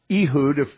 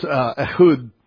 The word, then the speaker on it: Ehud: Then in verses 6 through 12, we have the genealogy of Benjamin through Ehud, or Ehud, however you want to pronounce it. It's fine with me. So in Hebrew, it's Ehud.